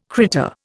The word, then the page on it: critter: Indian